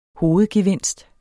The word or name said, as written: hovedgevinst